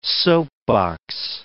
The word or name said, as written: soapbox